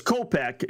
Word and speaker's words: Kopech: Kopech uh, is how you pronounce the White Sox guy.